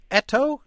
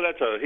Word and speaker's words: Eto'o: You wrote extensively about a player, and I hope I'm not mispronouncing his name too badly, called Eto'o? – Yes, very good. Yes, Samuel Eto'o.